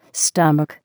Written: stomach

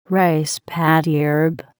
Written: rice paddy herb

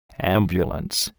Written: ambulance

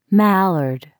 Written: mallard

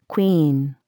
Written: queen

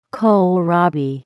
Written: kohlrabi

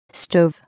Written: stove

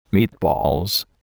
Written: meatballs

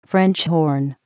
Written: French horn

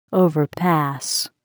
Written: overpass

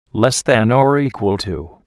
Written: less than or equal to

